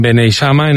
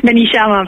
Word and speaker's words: Beneixama: Ella tiene 56 años, vive en Beneixama, en Alicante, y es profesora de FP Agraria. ¿Me equivoco, sí, en algo? Seguro que el nombre del pueblo lo he dicho mal. – Beneixama, pero vale.